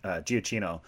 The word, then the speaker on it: Giacchino: His name is pronounced Giacchino.